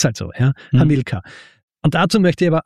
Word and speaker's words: Hamilkar: Und die Verwirrung ist naheliegend, weil Hannibal, spricht man so aus, Hannibal, Betonung auf der ersten Silbe, nur bei Hamilkar, da kommt eben, und das ist die lateinische Aussprache, ja?